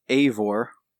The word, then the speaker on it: Eivor: It's pronounced Eivor.